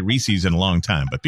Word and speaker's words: Reese's: A pet peeve that I have that I'll never get over is people pronouncing it Reese's. It is Reese's. It is a guy named Reese and it is his. It's his. Reese's. And it's in the commercial. Reese's Puffs, Reese's Puffs. R-E-E-S-E-S.